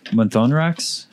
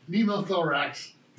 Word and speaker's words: pneumothorax: Pneumothorax. – There we go. – Pneumothorax.